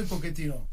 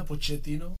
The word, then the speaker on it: Pochettino: Mais pour nous aussi d'ailleurs, comment prononce-t-on son nom ? Eh bien, il y a deux façons. – Como italiano que soy, Pochettino. Pero en Argentina, Pochettino. – Voilà, c'est comme vous le sentez.